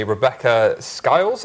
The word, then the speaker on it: Rebecca Skiles: Today, I have the one and only Rebecca Skiles.